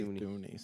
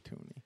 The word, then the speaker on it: Thuney: I say Thuney. Some people say Thuney.